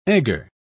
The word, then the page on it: egger: British English